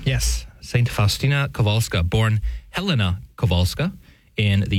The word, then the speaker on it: Kowalska: And if you're looking at it spelled in English, yes, it says Kowalska. I'm giving it at least close to the proper Polish pronunciation here, Kowalska.